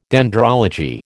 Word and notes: dendrology: American English

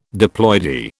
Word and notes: diploidy: British English

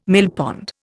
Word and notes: millpond: Indian

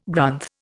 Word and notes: Grunth: Indian

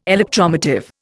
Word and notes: electromotive: Indian

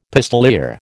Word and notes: pistoleer: American English

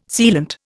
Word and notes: sealant: Indian

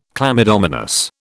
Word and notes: chlamydomonas: British English

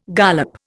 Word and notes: gollop: Indian

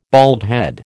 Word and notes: baldhead: American English

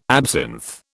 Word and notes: absinth: British English

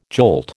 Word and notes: jolt: American English

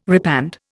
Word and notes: repand: Indian

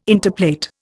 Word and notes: interplait: Indian

